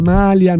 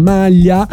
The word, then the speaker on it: malia: La malia di una strega, non la malia, non la maglia.